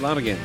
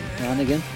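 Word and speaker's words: Lanegan: You can hear Mark Lanigan. Am I pronouncing that right? – Lanigan. – Lanigan.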